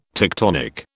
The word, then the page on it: tectonic: British English